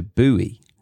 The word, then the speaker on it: buoy: And uh, the pronunciation in American is a buoy.